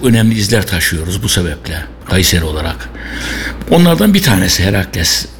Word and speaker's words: Caesar: Caesar diye yazılıyor biliyorsunuz, yani Latince Kayseri diye okunuyor.